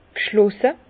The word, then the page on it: g' schlosse: Bas-Rhin; Herrlisheim